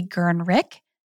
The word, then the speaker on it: Gernreich: And before we go any further, I just want to point out that it's Rudi Gernreich, not Gernreich.